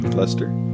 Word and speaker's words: Leicester: Leicester.